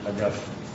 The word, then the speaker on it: Augustine: But the proper pronunciation of the Latin, in my opinion, is Augustine. Accent on the second syllable.